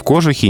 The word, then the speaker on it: кожух: Кожух в именительном падеже, ни в коем случае не кожух.